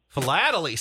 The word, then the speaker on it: philately: I put the pronunciation there. – Thank you. – Philelity. – Philelity? – I think so. – What is... Here we go. Just to be correct. Philately. See, Carissa was even wrong.